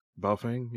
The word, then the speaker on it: Baofeng: I've heard that, how do you pronounce it? Baofeng? – Baofeng, yeah.